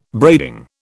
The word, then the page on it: braiding: British English